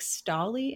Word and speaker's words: Scalli: You worked with the Boston Sea Rovers and it was a Frank Scali internship. Am I saying that correctly? – Yeah. So Frank Scali, S-C-A-L-L-I.